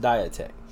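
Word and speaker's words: diatek (διαθήκη): It's not spelled like it sounds, but it's pronounced diatek.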